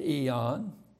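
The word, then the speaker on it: eon (αἰών): There is a little word, A-I-O-N, eon. We pronounce it eon.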